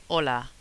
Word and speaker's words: hola: We don't pronounce the H. It's there, but like if it's not there. Like when we say hola. – Hola. Mm. And also the verb hablar, for example. – To speak, yeah. – Yeah. – So if you think about English when you say our, you don't say hour. – That's right. – So it's that kind of H. It's a silent H.